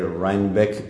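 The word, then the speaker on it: Rhinebeck: Rheinbeck. – No sé si se pronuncia correctamente. – Muy bien, te has hecho perfecto. Además le has puesto un poco de acento alemán.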